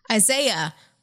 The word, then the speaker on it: Isaiah: Isaias. Did I say it right? – Isaiah. – Isaiah. – I called it.